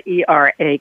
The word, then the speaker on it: era: First of all, I want to commend you for pronouncing the word ERA correctly. It is era, and you pronounced it beautifully.